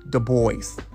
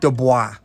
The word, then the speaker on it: Du Bois: It's W.E.B. Du Bois, not Du Bois. Like some of y'all trying to make it out to be.